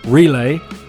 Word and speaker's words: Relai: Relay are a company this side of the pond in Europe that do all of those same services. It's spelled R-E-L-A-I and it's .ch/bitten, and use the code BITTEN when you're setting up your account.